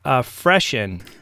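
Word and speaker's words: Fressian: It was inspired by Hessian, and Hessian is pronounced Hessian, so I'm going to call it Freshen.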